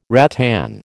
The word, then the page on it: rattan: American English